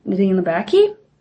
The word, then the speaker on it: Nadine Labaki: And I definitely want to go back and watch some movies from Nadine Labaki.